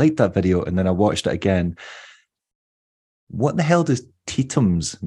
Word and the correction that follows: Tetons: Sorry, I'm not saying it right. Tetums. – Tetons. – Tetons, that's it. Tetons.